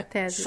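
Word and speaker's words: Tezos: A primeira é Tesos, ou Tesos, eu não sei qual a pronúncia. – É Tesos.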